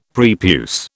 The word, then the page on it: prepuce: British English